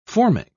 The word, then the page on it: formic: British English